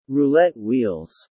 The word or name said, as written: roulette wheels